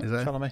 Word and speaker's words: Chalamet: Timothy Chalamet. – That's right. Chalamet.